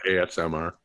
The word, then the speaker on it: ASMR: And it's sort of AMSR. Is that how you say it or is it ASMR? – ASMR.